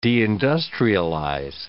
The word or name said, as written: deindustrialize